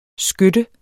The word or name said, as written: skytte